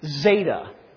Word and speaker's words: zeta: And it kind of looks like a Z, and it's pronounced like a Z, or maybe better, it's pronounced like a DZ, all right? And this is how you would say the word, zeta, DZ, kind of looks like a Z.